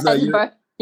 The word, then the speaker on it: Edinburgh: Edinburgh, am I saying that right? – Yeah, Edinburgh.